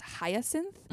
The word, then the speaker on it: Hyacinth: And I appreciate I had a really hard time in the book, the youngest sister, her name is Hyacinth.